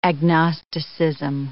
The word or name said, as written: agnosticism